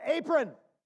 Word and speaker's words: aphron: The Greek word for foolish in 1 Peter 2:15 is spelled A-P-H-R-O-N, but it's pronounced like this cooking garment, apron.